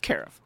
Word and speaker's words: carafe: Is it carafe or carafe? – Carafe. – Carafe, okay.